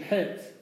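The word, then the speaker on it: het (חית): That's how Svardim say ches. That's the real proper pronunciation het.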